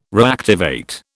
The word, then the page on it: reactivate: British English